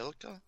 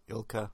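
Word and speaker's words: ILCA: Is that how you spell it? Ilka? – Ilka, yeah.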